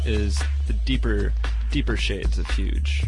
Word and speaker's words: Hooj: speak of very influential pioneering record labels, we said Bedrock, but we've got to talk about Huge. Huge Tunes is a classic label. It's spelled H-O-O-J, based out of Europe, and they're not around anymore, sadly.